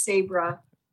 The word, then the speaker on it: Sabré: I have to first ask if I'm pronouncing your name right, because I've not come across another Sabré, if that's the correct way to say it. – It is right. I'm actually very impressed. It is Sabré.